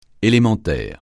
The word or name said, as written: élémentaire